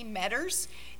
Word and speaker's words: Meader: We'd ask you to add Jess and Amy Metters. It's spelled like meter, M-E-A-D-E-R, but it's pronounced Metter,